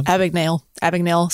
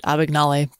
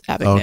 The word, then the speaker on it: Abagnale: Abagnale. So it was funny in the movie. They mispronounce it Abagnale, but it's Abagnale.